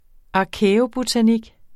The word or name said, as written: arkæobotanik